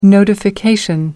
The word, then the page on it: notification: American English